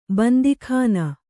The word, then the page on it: ಬಂದೀಖಾನೆ: bandī khāne